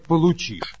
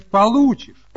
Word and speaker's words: получишь: Николай Владимирович, нельзя говорить «получишь». Надо говорить «получишь». Вы неправильное ударение ставите.